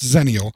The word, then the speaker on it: Xenial: And Xenial is a term, and though it's spelled with an X, it is pronounced Xenial.